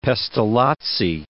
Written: Pestalozzi

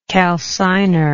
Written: calciner

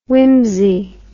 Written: Wimsey

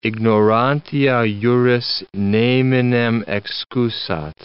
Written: ignorantia juris neminem excusat

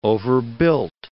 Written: overbuilt

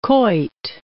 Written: quoit

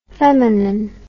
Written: feminin

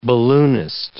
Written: balloonist

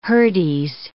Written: hurdies

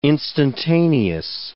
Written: instantaneous

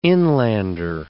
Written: inlander

